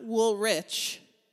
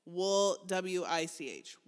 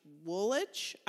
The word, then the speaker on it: Woolwich: Warren, not Wool Rich. Wool, W-I-C-H. Woolwich? I don't. People in Great Britain pronounce things that seem like they should be pronounced one way, a totally different way.